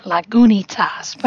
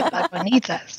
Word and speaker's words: Lagunitas: Yes, they have all the other Lagunitas beers, and I always want to pronounce it Lagunitas, but I'm making an effort to say Lagunitas today.